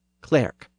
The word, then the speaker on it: clerk: Chaucer probably pronounced the word clerk with its original short e sound, clerk. That's the same vowel that we still have in the word cleric. So if you drop that I in cleric, you get clerk.